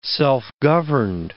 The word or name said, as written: self-governed